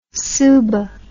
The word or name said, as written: subah